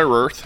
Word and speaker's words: Roth: Is that how I say that Roth. – Right? Am I pronouncing it right? – Uh-huh. Eli Roth.